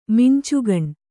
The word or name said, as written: ಮಿಂಚುಗಣ್ಣು